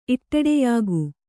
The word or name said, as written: ಇಟ್ಟೆಡೆಯಾಗು